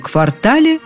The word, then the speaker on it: квартал: В этом квартале нужный дом. Правильно именно квартал. Орфоэпический словарь под редакцией Аванесова тут резок и однозначен. Никаких вариантов нам не предлагает.